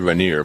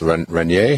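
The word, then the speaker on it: Rainer: I say Rainier, but it's Rainier. How do you say that? – I think it, you know, I'm really not sure. I think it is Rainier.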